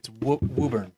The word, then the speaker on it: Woburn: Woburn? – It's Woburn. – Woburn. – Woburn.